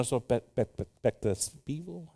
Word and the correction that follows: perspectiva: How do you say it? Perspective? Perspectivo. Ay, ¿dónde están los mexicanos hoy? No los dejamos entrar. Perspectivo. ¿Cómo? Perspectiva. Perspectiva. Ok.